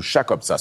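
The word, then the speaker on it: Chakobsa: There's a language, the Fremen language, Chukotka. Am I saying that correctly? – Absolutely, Chukotka.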